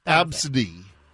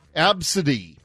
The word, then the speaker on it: Abcde: So we told you the story yesterday about the mom who named her daughter A, B, C, D, E. – Mm-hmm. – Pronounced Absidy. Absidy.